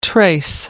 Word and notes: trace: American English

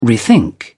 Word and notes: rethink: British English

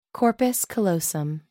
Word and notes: corpus callosum: American English

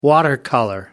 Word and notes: watercolour: American English

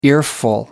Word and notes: earful: American English

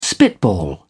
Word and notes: spitball: British English

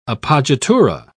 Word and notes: appoggiatura: British English

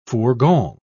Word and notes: fourgon: British English

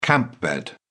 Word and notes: camp bed: British English

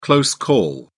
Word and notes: close call: British English

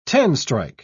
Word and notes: ten-strike: British English

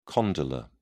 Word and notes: condylar: British English

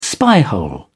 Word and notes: spyhole: British English

